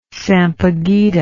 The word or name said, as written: sampaguita